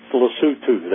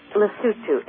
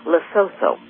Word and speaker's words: Lesotho: How do you pronounce that correctly? – That's a pretty good effort. It's Lesotho. That is correct. – Lesotho. All the rest of us pronounce it Lesotho. – That's the American way to do it.